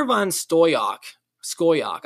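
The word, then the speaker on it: Van Scoyoc: Connor von Skoyak, I believe is actually how you pronounce his name, is currently 23 years old.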